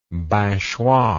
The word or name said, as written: binchois